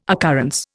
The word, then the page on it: occurrence: Indian